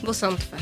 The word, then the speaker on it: Bosomtwe: Cześć e, Oliwia Bos Bosmotwę, dobrze mówię? – Bosątwę. – Bosątwę, właśnie.